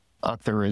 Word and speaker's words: Akhtar: Akhtar is AKHTAR.